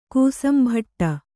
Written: ಕೂಸಂಭಟ್ಟ

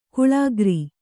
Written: ಗಳೆಕಾರ

